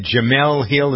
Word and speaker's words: Jemele Hill: You know what I really wanted to spend a minute is it understanding this ESPN, is it Jemele Hill? Is that how you pronounce? – Yeah.